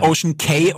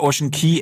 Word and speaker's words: Ocean Cay: Tatsächlich Ocean Cay oder Ocean Key, es gibt beide Aussprachen sind, glaube ich, richtig. – Ich hätte jetzt immer intuitiv K gesagt, weil es C-A-Y geschrieben ist. Damit wäre es für mich das K.